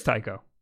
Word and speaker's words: Taiko: But I think Taiko, is that actually how you pronounce it? – Yeah. – Oh, it is Taiko.